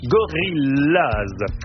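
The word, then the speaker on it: Gorillas: Gorillaz se prononce comme en anglais. Gorillaz.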